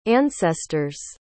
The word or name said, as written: ancestor's